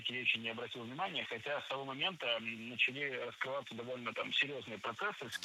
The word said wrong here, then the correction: начали: Начали неправильно. Должно быть «начали». Ударение в этом слове падает на первый слог.